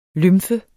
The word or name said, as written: lymfe